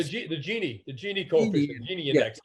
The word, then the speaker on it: Gini index: I'm hoping I'm pronouncing this correctly, it's the Gini index, or is it the Gini index? – The Gini index. Yeah, that's right.